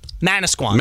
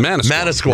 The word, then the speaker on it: Manasquan: How do you say the name of the city? The town? – Manasquan. – Manasquan. Right.